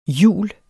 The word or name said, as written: hjul